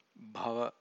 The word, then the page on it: bhava: Pali